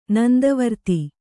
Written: ನಂದವರ್ತಿ

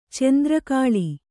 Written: ಚೆಂದ್ರಕಾಳಿ

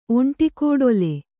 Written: ಒಂಟಿಕೋಡೊಲೆ

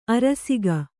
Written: ಅರಸಿಕ

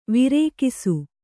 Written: ವೃಕ್ಷ

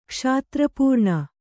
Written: ಕ್ಷಾತ್ರಪೂರ್ಣ

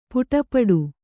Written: ಪಡುಪಾಟು

